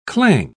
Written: clang

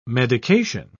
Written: medication

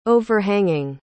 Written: overhanging

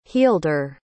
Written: healder

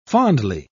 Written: fondly

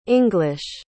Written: english

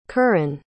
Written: curran

